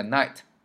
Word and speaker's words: Knight: 那它叫黑暗骑士，Night, K-N-I-G-H-T, K-N-I-G-H-T。大家注意到了，它有两个特点：第一，它和夜晚的那个 night 发音是一致的；第二，它就是那个夜晚的 night，前面加了个 K 而已。